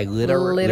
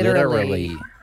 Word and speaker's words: literally: Yeah, you're chucking an extra syllable in there. – An extra L? – Literally? Just go literally. – Literally. – Great. You almost got it. Try one more. – Literally. – No, too many L's.